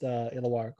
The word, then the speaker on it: Illawarra: And I'm legitimately got warm fuzzies from hearing that I pronounced Illawarra correctly.